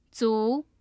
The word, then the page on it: zu: 2